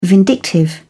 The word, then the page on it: vindictive: British English